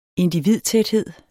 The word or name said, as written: individtæthed